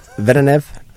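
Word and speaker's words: Vedeneyev: And you have the, I'm going to say this wrong, the Vedenev engine on the front of it? – That's correct. – Did I get it close? – Yep, Vedenev, yeah.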